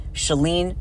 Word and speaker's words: Shalene: The same way saying my name, Shalene, Shalene, Shalene. Let's just practice. It rhymes with Celine. Celine is not saline, right? Saline is an implant. I am not Shalene. I am Shalene, which rhymes with Celine, like the singer.